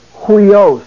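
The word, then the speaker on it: Hui os (υἱός): And it is the rough breathing mark. Therefore, an H sound is to be pronounced before the letter. But since it's a diphthong, the H sound is pronounced in front of the diphthong. So instead of it being we, it's hui. Hui os.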